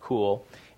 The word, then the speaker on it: Cuil: And we're really thrilled to have her here because she's going to talk to us about her new venture, which is called Cool, spelled C-U-I-L but pronounced Cool, and we can maybe hear a little bit about what the origin of that is.